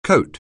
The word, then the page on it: coat: anglais